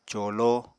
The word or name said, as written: colo